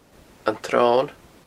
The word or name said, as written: antsron